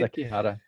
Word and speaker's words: Sekihara: And I'm really struck that this book, Kuni, that you wrote with, I'm going to get the author's name wrong, Sekihara, is that right? – Sekihara, yeah.